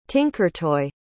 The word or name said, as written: tinkertoy